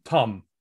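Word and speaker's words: Tâm: It's spelled in the book as Tam with an accent mark over the A, but Tum is how it's pronounced.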